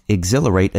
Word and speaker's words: exhilarate: Many people find exhilarate a difficult word to spell. It's a great word for a spelling bee. It's easy to forget that silent H in there, and is it an ER or AR after the L?